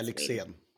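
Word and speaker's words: Lyxzén: if Karen O and Dennis Lixon had a, sorry, is it Lixon? Is that how you say it? – Yeah, Lixian.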